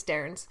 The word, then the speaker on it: Dairnes: I don't know how to pronounce that. D-A-I-R-N-E-S. It's a made-up word, so I can pronounce it however I like, and I think it's Dairns. – I said Dairns, too.